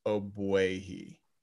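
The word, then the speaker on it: Ogbuehi: According to the internet, which is never wrong, the way to pronounce his name is Agbuehi. There's no G. The G is silent.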